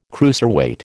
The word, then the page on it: cruiserweight: American English